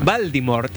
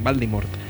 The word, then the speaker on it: Voldemort: Voldemort, acabado en T. Y se escribe con V, Voldemort. ¿Vale? Cuando, o sea, si lo tienes que decir algún día en el corto, pues que sepa decirlo bien, Voldemort.